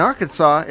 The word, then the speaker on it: Arkansas: In Arkansas, it's a felony to mispronounce the state name, so remember, it rhymes with Santa Claus.